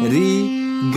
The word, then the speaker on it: ri: So I think it is sa, ri, ga, ma, pa are the correct pronunciations. – Wow, wow. – But in North Indian, they have become sa, re, ga, ma, pa.